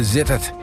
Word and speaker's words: Sittard: Of zoals ze hier zelf zeggen, Zittert. Of Zitter, eigenlijk. Die D spreek je bijna niet uit, Zitter.